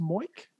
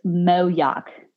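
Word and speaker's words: Moyock: Is it pronounced Moik? Maybe. Nope. Mayoc. White flag. I'm waving the white flag. Everybody says Moyoc. It's Moyoc.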